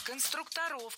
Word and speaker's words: конструкторов: Вот именно конструкторская команда, навскидку, это сколько было человек, вот отряд конструкторов? – Конструкторов, конструктора, это неверно. В слове «конструктор», опять же, ударение не меняется. Всегда сохраняется на «у» во втором слоге. Конструкторы, конструкторов, конструктором.